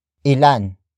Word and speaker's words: ilan: Word for word, it's ilan, ang, nakapasa, ilan. Ang nakapasa. Ilan ang nakapasa. The first word is ilan, which is spelled as I-L-A-N.